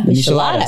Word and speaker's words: Michelada: Michelada.